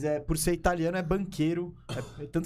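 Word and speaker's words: Banchero: Polêmica da pronúncia do nome do banqueiro. Tem gente falando que é bancheiro. Né? Banqueiro. É banqueiro porque ele é descendente de italiano.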